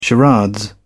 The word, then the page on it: charade: British English